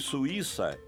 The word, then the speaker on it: Suíça: No caso de Suíça, estamos falando de hiato, U-I, pronunciado separadamente. E por isso o I, que nesse caso é tônico, leva acento.